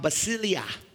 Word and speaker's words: basileia: Kingdom in Greek is pronounced basileia, which means kingship or to have a royal rule.